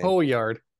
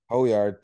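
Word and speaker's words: Højgaard: Um, Nikolai Hoyt, say his name. Hoyard. – Hoyard, thank you.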